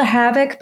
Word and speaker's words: Havick: It's called the Havoc, but it's spelled H-A-V-I-C-K.